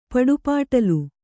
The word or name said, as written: ಪಡಿಪಾಟ್ಲು